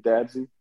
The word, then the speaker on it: Dadzie: Facebook is David Dadzie. The last name is spelled D-A-D-Z-I-E.